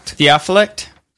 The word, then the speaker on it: Theophylact: How do you say his name? It's Theophylact. – Theophylact.